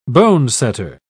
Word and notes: bonesetter: British English

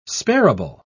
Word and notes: sparable: British English

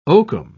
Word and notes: oakum: British English

